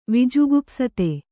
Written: ವಿಜುಗುಪ್ಸತೆ

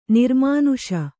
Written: ನಿರ್ಮಾನುಷ

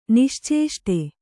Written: ನಿಶ್ಚೇಷ್ಟ

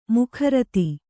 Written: ಮುಖರತಿ

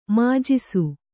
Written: ಮಾಜಿಸು

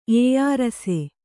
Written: ಏಯಾರಸೆ

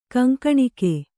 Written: ಕೊಂಕಣಿಗ

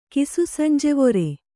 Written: ಕಿಸುಸಂಜೆವೊರೆ

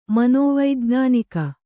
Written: ಮನೋವೈಜ್ಞಾನಿಕ

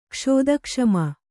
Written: ಕ್ಷುತ್ಕ್ಷಾಮ

